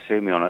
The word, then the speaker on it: Soumillon: Soumillon, is it? The Aga Khan. – You're referring to Christophe Soumillon at Saint-Cloud just a couple of days before the Arc.